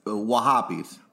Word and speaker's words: Wahhabis: I think you said that wrong. It's Wahhabis.